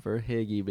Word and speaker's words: Verhaeghe: I mean, Leafs legend Carter Verhaeghe. Am I saying that properly? – Verhaeghe, baby.